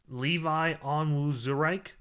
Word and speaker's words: Onwuzurike: Watch me try to pronounce this. Levi Onwuzurike from Washington, defensive tackle. I think I did. – That was pretty good.